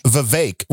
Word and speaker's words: Vivek: It's Vivek, rhymes with cake.